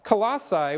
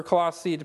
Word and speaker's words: Colossae: Colossae, which is, or Colossae, depending on the person that you're listening to pronounce it, is a little town that was 100 miles outside of Ephesus.